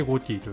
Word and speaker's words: GOTY: まずはイワゴティってなんやねんってところから話をしていこうと思います。イワゴティっていうのはその名前の通りあなたのゴティを決めましょう、 2024 年のゴティを決めましょうというものでして、ゴティというのは GOTY と書いてゴティと読むんですけど、